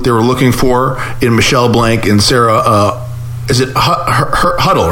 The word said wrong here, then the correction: Hurley: right? – Sarah Hurley. – Hurley. I don't know why I always have her. Her name is very easy, Hurley, but I always say it wrong.